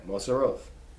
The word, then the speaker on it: Moseroth: Pronounce it there, Moseroth.